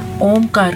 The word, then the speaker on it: ओम: ओमकार शब्द, ओम जो शब्द है वो हमारा 3 अक्षरों से मिलकर बना है, आ, ऊ और मा। तो इसको उच्चारण करने का सही तरीका मैं आज आपको बताऊंगी। आ का उच्चारण थोड़ा कम देर के लिए, ओ का उच्चारण उससे थोड़ा ज्यादा, और माँ का उच्चारण थोड़ा डीप करते हैं।